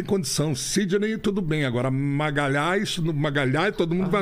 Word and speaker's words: Magalhães: E como a Itália era o lugar onde eu ia mais fazer as apresentações, um empresário italiano virou e disse Magalhães. Ah, era... Magalhães na Itália não pronuncia por causa do LH. Maga...